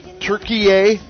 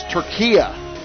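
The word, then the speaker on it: Türkiye: national team because, to me, tonight's game with, I've heard Turkey A, and I've heard another guy called in to correct the pronunciation. It's Turkia.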